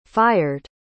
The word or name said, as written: fired